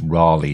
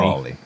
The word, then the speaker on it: Raleigh: Raleigh, John. Not like the bicycle that we have over here. – Okay, not Raleigh. – Raleigh.